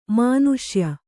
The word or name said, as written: ಮನುಷ್ಯ